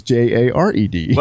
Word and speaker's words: Jared: Well, my girlfriend would go with J A R E D because she makes fun of me all the time and says that I mispronounce my name and that it should be Gerard and not Jared.